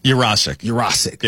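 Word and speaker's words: Yurosek: And because Usyk? I always get his name wrong. Ben, the tight end. – Urosik.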